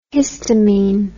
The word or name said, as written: histamin